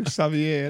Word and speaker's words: Xavier: Ja, det uttalar precis som det hörs. Alltså, Xavier.